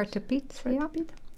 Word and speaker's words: Zwarte Piet: and correct me please if I pronounce it wrong, but in Dutch is Zwarte Piet. – Yeah, very good. Yeah. Zwarte Piet.